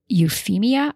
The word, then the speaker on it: Euphemia: It's called St. Euphemia. I think I'm pronouncing that correctly. It's E-U-P-H-E-M-I-A.